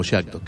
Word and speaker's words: Jacto: Se escribe Jacto y generalmente por una cuestión cultural nosotros en Argentina lo pronunciamos como Jacto, que es lo más fácil que nos sale siempre.